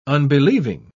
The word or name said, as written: unbelieving